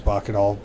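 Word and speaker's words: Bakkedahl: Okay, because most people pronounce my name Clam, and it's Clom. And so yeah. I always do the reverse, I always assume. – Well, when you have the double consonant, you're supposed to elongate the vowel. – Right, right. – And we don't. – Right. – My family doesn't, but that is the correct pronunciation, is Bacadal.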